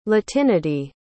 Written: latinity

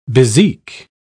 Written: bezique

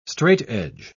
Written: straightedge

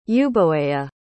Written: euboea

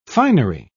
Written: finery

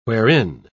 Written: wherein

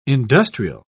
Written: industrial

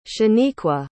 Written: shaniqua

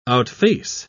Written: outface

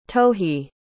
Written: tohy

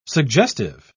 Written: suggestive

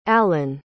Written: allen

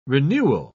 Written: renewal

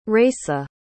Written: raisa

